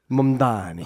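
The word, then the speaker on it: Mamdani: Mem Danny here in New York is... – Mem Danny? Who says his name like that? – Mem Danny? How do you say it? – Mumdani.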